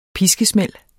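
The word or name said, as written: PISKESMÆLDET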